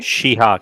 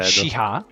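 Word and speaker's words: Chiha: Patrick Kiha, credo che si pronunci, Shiha, credo. – Shiha, ok. Che è stato presentato alla 73esima mostra berlinale, berlinale, sì.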